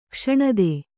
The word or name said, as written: ಕ್ಷಣದೆ